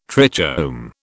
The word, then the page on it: trichome: British English